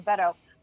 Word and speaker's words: Beto: Beto.